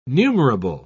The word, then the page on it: numerable: British English